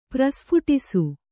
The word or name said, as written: ಪ್ರಸ್ಫುಟಿಸು